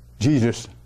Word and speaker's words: Jesus: Comment on dit en anglais, comment on prononce en anglais le nom de Jésus? On dit Jesus, hein? Jesus. Jesus, c'est ça. Comment de personnes disent G? Ou Jewish? Jewish. C'est tellement inoffensif, mais c'est la première syllabe du nom de Jésus en anglais.